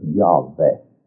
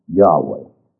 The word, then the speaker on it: Yahweh: You will find a few people who are aware of the name of God who pronounce it as Yahweh, a V instead of a W, but most scholars are agreed on Yahweh.